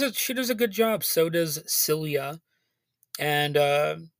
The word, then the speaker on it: Silje: But the main star who plays Jenny is Silja Salomonsson. And I had to look up how to pronounce that first name because it's spelled S-I-L-J-E. And it turns out that's Silja.